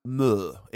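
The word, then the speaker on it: Mø: And then there was a Danish woman who, I believe it's pronounced Mur. It's M with the O with a line through it, you know what I'm talking about? – Yeah, okay.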